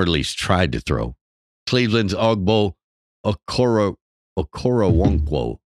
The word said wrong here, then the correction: Okoronkwo: broke. Through the, am I saying that right? Okoronkwo broke through the line and sacked Burrow.